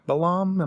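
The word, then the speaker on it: Ek Balam: The first place that you went to right away, like Ekbalam, am I saying that right? – Yeah, I think you are.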